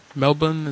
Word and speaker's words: Melbourne: Melbourne, is that how you pronounce it? – Oh, yeah.